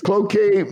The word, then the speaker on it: Cloquet: Cloquet. I probably butchered the name. Cloquet. I knew I'd mess that up. Cloquet. – Yeah, it's a tricky one. Not many people are from Cloquet, so there's only a handful of us that have to know how to say it.